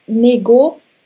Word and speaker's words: него: Книгу, genau, hier spricht man das G, него, hier spricht man das G, W aus, also для него книгу.